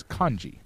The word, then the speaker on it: kanji: So the correct pronunciation of the character system in Japanese is kanji, because... K-A is always rendered as ka, okay? – Yeah.